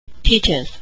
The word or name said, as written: teaches